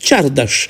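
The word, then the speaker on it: csárdás: Nu se interesase ea câtuși de puțin, nici nu întrebase măcar cum se pronunță Xardas în maghiară, acel dans tradițional unguresc, pe care noi îl pronunțăm corect Xardas, scris însă cu ks, dar desigur ce să aștepți de la francezi,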